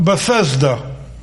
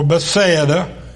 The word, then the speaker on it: Bethsaida: Now Philip was of Bethsaida, or Bethsaida, two different ways to pronounce it, both are acceptable, Bethsaida or Bethsaida, the city of Andrew and Peter.